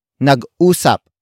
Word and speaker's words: nag-usap: Word for word it's Nag-usap.